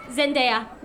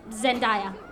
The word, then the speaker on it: Zendaya: It does not rhyme with papaya. It's Zendaya, not Zendaya.